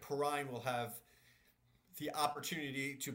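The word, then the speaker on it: Perine: Pick one, PPR, Sermon, Gainwell, Gaskin, or is it Perrine? I don't even know how to say his last name. – Samaj Perrine.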